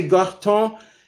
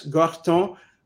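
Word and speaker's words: Gortons: Okay, there are two ways of saying it. That some say garton, and that's G-O-R-T-O-N-S, garton. – Yes. – Some say creton, C-R-E-T-O-N-S, and they're both acceptable.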